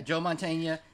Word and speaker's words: Mantegna: Yeah, Joe Mantegna, yeah.